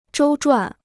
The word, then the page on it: 周转: zhōu zhuǎn